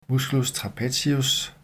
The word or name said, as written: m. trapezius